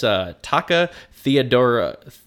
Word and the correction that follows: Taki Theodorakopoulos: Taki Theodorakopoulos said...